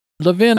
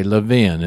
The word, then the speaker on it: Laven: Laven, I am saying your name correctly, right? – 100%. – Okay, Laven.